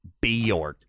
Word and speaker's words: Björk: Her name is pronounced Björk. When you see the little umlauts on top.